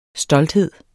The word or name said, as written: stolthed